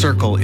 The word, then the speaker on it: CRCL: CRCL, pronounced Circle, is a mobile, community-led crisis response service in the Comox Valley.